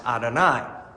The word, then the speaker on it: Adonai: So they put the Hebrew letters with the vowels of the word Lord, which is pronounced Adonai.